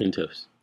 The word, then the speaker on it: Intuos: She wants, as a student, she was recommending the Wacom Intuos. – Yep. – How do you say that? – You did it. – Intuos.